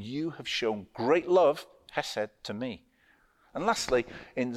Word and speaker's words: chesed: The problem with spelling it C-H-E-S-E-D is that some people pronounce the ch like cheese. Chesed doesn't work. But there is a K-H. It's a hard sound. It's in the back of the throat. Chesed. And to help our pronunciation of this, some people spell chesed K-H-E-S-E-D, or they spell it with a hard H at the front. Chesed. H-E-S-E-D.